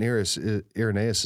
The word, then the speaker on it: Irenaeus: And the quote by St. Irenaeus is that, did I just mispronounce Irenaeus? That's on me. On me. No, you got it right.